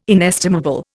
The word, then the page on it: inestimable: Indian